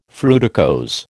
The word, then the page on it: fruticose: American English